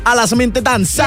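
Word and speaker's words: Alla som inte dansar: Der er kommet bud på maskinen Alle som Inte Danser, ja. Tror jeg, man udtaler det. – Alle som Inte Danser. – Ja, lige præcis.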